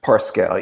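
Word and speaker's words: Parscale: Is that how you pronounce his name? – Pascal, yeah.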